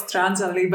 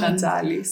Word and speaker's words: Strantzali: Yes, well, it's Strantzali, but everybody says it's Strantzali, so.